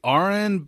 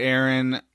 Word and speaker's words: Arryn: It's pretty clear to him that Aaron, is that how you say his name? Aaron or Arryn? – I say Arryn, but I've heard people say Aaron.